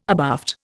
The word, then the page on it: abaft: Indian